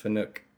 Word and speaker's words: Fanuc: If it's Japanese, it's pronounced Fanuc. I don't understand the Fanuc. – Wars have started over that. – I know. I don't know, but Japanese pronunciation is Fanuc or a Fanuc, whatever.